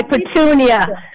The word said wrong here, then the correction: Petrunia: No, so it's how do you say it? Petunia? – It's with an R. Petrunia.